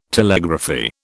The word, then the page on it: Telegraphy: British English